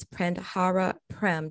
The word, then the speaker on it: Prandhara Prem: Her name is Prandhara Prem. Did I say your name properly? – You did. Good job. Perfect.